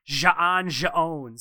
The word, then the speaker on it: J'onn J'onzz: J'onn J'onzz because there's an apostrophe. – That's how you so pronounce that.